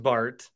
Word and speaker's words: Bart: It's just Bart. And I always I like the name. I like that there's a uniqueness to it. I don't like that it rhymes with fart.